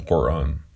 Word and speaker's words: Quran: If anything, hardly any even know what it says in the Quran, or what's pronounced the Quran. Now, the reason I'm pronouncing it in the original way, in the Arabic or Persian type style,